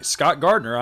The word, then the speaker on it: Scott Gardner: And hey, you can friend me, Scott Gardner, on Facebook, too. My name is spelled S-C-O-T-T-G-A-R-D-N-E-R.